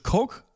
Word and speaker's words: Koch: Und ähm, ich habe ein paar interessante Sachen gelernt, zum Beispiel, dass Koch auf Englisch Coke ausgesprochen wird.